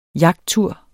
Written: jagttur

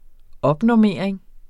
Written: opnormering

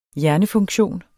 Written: hjernefunktion

